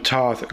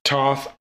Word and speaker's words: Thoth: His name is usually pronounced Thoth or Thoth, but both are translations of the more accurate Tehuti or Jehuti.